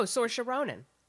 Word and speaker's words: Saoirse Ronan: Oh, Saoirse Ronan.